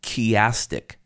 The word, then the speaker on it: chiastic: It uses what we talk about in biblical study, a chiastic structure, and you spell that C-H-I-A-S-T-I-C, chiastic structure.